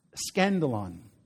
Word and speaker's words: skandalon: Now, this word hindrance that we have in the English Standard Version of the text is from the Greek word, which is pronounced skandalon.